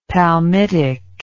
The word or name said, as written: palmitic